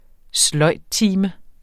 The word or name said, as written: sløjdtime